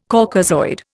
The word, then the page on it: Caucasoid: Indian